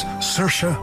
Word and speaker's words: Saoirse: So please, remember, it's Saoirse Ronan, not She Sells Seashells Ronan.